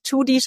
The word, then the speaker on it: Tschudi: Und Judy schreibt man mit T-S-C-H-U-D-I.